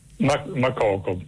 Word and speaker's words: Makoko: Mokoko con l'accento? – Makoko, sì, non so se con l'accento finale alla francese è Makoko, però probabilmente siccome quello è un paese anglofono sarà in maniera diversa.